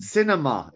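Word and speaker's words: Sinema: Cinema. It's just cinema.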